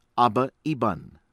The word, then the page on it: Abba Eban: American English